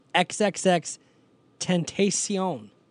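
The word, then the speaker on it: XXXTentacion: It is pronounced XXX Tentacion.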